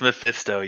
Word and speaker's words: Mephisto: Am I saying it wrong? Is it Mephisto? – It's Mephisto, yes.